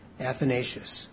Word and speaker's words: Athanasius: In case you want to write it down, I'll spell it for you. It's A-T-H-A-N-A-S-I-U-S. Athanasius.